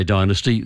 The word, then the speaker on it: dynasty: So the Americans say Dynasty. They're wrong. The word is Dynasty. It's an old English word, and the standard accepted pronunciation is Dynasty.